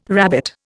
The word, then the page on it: rabbit: Indian